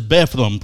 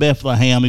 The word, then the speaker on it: Bethlehem: Pikeville versus Bethlehem, probably. Bethlehem. It's not Bethlehem, even though it's spelled that way. They pronounce it Bethlehem. – All right. You say Bethlehem, you sound like an idiot.